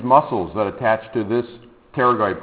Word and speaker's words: pterygoid: and they're called the pterygoid process. Notice you do not pronounce the P, but when you spell it out, that P has got to be there.